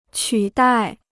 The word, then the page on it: 取代: qǔ dài